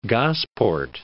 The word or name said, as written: Gosport